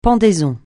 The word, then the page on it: pendaison: francais